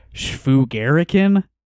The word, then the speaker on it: shvugarican: Uh, the man was apparently 20 or not much more and he looked like what politically incorrect New Yorkers call a schfugerican. okay, I'm assuming neither of you have heard that word. Schfugerican. It's spelled S H V U G A R I C A N.